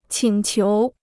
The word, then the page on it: 请求: qǐng qiú